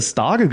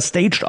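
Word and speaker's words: Stage: Jens, ich hätte Starge gesagt, aber er wird ja staged ausgesprochen angesprochen.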